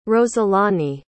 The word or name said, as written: roselani